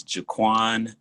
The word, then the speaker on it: Jaquan: So I just refer to my name as Quan, but my name is Jaquan Newsom. You spell that J-A-Q-U-A-N.